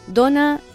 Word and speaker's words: dona: Ara bé, volem cridar-vos l'atenció sobre la pronúncia i l'escriptura dels mots dona i dona. El substantiu dona, referit a la persona, a la persona de sexe femení, es pronuncia amb O oberta i no s'accentua.